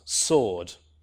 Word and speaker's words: sword: And this is the same in a word like sword. So we see in the Old English, sweort, which is pronounced with a W, sweort, but in modern English, it's not pronounced sword, right? It's pronounced sword. So it's always pronounced without the W after the S.